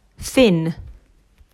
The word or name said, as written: Thin